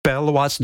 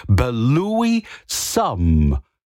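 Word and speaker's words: Belouis Some: Belwas, no, not Belwas, das ist for Problem. Ich habe es immer falsch ausgesprochen. Es ist Belui Some Imagination.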